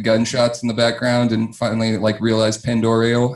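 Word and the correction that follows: Pend Oreille: How do you say that? Pandorai? – Pandorai County is invading Stevens County in the leadership vacuum.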